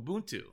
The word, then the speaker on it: Ubuntu: and tell me if I'm pronouncing this right, Ubuntu? – Ubuntu.